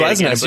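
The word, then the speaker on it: Glesnes: And Joe, how do you pronounce their Norwegian right center back's name before I say Glessness? You nailed it.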